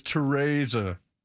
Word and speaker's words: Teresa: Say it right, Mr. Wrongsayer. Teresa. It's Teresa. Teresa Hines.